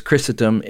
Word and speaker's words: Chrysostom: It's spelled weird, but it's Chrysostom.